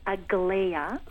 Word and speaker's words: Aglaia: Her name is Aglaia. – Okay. I was close. – Yeah, well, pretty good. I did write near the beginning, it rhymes with Alpeia.